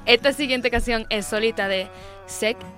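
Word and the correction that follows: Sech: ¿Ese cómo se dice? – Sec.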